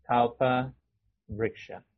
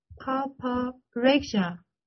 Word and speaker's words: Kalpa Vriksha: No, no, no, no, no, you're not pronouncing properly. – Kalpa. Can you read that? – Yes, Kalpa Vriksha. – Kalpa Vriksha. – Yes, Vriksha.